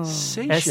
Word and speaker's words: Seychelles: The country's name, if the first vowel sound is changed, answers the question, what does she sell at the seashore? – Oh, the Seychelles. – Whoa. Seychelles. – S-E-Y-C-H-E-L-L-E-S, right? – Right.